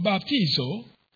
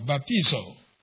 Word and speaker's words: baptizo: Now that is translated from a Greek word, baptizo. Almost, if you take that word, baptize, and put an O, remove ED and put O, but a long O in the Greek, which is omega.